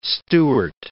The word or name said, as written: Stewart